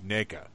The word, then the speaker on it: NECA: the correct pronunciation is indeed NECA.